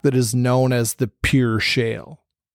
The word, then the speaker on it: Pierre: And that's Pierre, but it's spelled like Pierre. I always think of it P-I-E-R-R-E, Shale. So you might see it, you know, spelled and think, oh, that's not what we're saying.